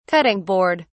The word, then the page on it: cutting board: British English